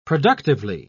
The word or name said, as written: productively